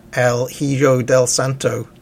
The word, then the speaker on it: El Hijo del Santo: El Hijo del Santo.